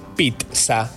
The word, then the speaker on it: pizza: Eh, sí, la RAE dijo, algunos le dicen pizza, otros pizza, otros femicidio, em, pero la forma correcta de pronunciar es pizza.